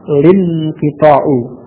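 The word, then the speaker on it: lin-kita'u (الانقطاع): Inqita, hamzahnya wasal, dimasuki alif lam, al-inqita'u. Jadi bukan al-inqita', diucapkan tidak seperti itu, tidak al-inqita', tidak seperti itu, karena ini hamzahnya wasal, maka hamzah wasal pada inqita' tidak diucapkan, maka diucapkan ketika dimasuki alif lam, diucapkan al-inqita'. A-lin-kita'u, ya. Kemudian tadi diawali fi'il istamarra, A-nya pada hamzah wasal atau pada alif lam tidak perlu diucapkan, jadi langsung lin-kita' wastamarra lin-kita'u, ya.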